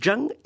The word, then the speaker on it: Zheng: Donc, Zheng et Qi, mais je le prononce très mal. – C'est Zheng et Qi.